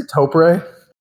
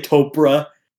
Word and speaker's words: Topre: Did you say Topre? – Yeah, that's what it is. – Is that how it's pronounced? – Yeah. – Fuck. – I think some people say Topra. That's or whatever.